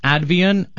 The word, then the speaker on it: Advion: It is pronounced Advian, and it's spelt Advion.